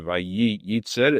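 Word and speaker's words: Vayitzer (ויצר): Notice, though, the Zohar HaKadosh says that in the Vayitzer, we pronounce only one of the Yuds. We don't say Vayitzer, it's just Vayitzer, which means one Yud is open, pronounced, the other yud is silent.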